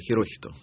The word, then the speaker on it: Хирохито: Не положено ударение, нужно так ровно. Хирохито.